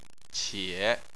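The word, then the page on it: qie: tone 3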